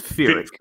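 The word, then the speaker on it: Pyrrhic: It's P-Y-R-R-H-I-C. I think it's Fyrik.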